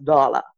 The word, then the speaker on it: doula: So I know people pronounce it doula, but it's actually pronounced doula.